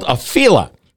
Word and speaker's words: Afeela: So many questions, because what we haven't heard about the Sony Honda car, and by the way, it's called a Fiela. Let me spell it. A-F-E-E-L-A.